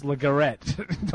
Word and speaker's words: LeGarrette: I'll go with LeGarrette Blount. Mid-round, I think they'll take a chance on him. – First of all, it's LeGarrette. Don't you know how Fred pronounces it? – Well, that's true, yes.